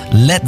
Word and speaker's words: Led: Led Zeppelin. Das A in Led ließ er einfach weg, damit es nicht wie Blei ausgesprochen wird.